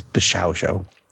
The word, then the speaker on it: Bishoujo: Well, you said it incorrectly. Yeah. What? It's Bishao Show.